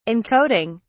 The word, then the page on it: encoding: British English